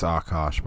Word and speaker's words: Akaash: I think it's Akash, but we'll call it whatever you want.